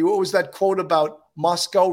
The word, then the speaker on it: Moscow: town of Moscow, pronounced not like the Russian capital, but to rhyme with Costco.